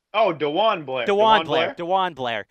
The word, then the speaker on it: DeJuan Blair: They added Justin Burrell and Jawan Blair. I always butcher that one, but the former Pittsburgh standout. – DeJuan Blair.